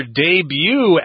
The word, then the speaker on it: debut: Debut is the correct pronunciation. Remember, English is a garbage language. English borrows words from all sorts of different languages, including French. And debut is using the French pronunciation there. You just don't pronounce the T at the end of this word, no.